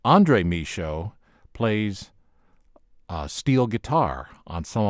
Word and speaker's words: Michot: And Michaud is spelled M-I-C-H-O-T, French pronunciation, Michaud.